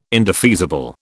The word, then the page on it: indefeasible: British English